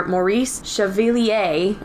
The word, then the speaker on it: Maurice Chevalier: Maurice Chevalier, I believe that's how you say it. Good job.